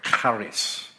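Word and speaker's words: charis (χάρις): And the word grace, charis, here speaks, charis. That's how they pronounce it, they say.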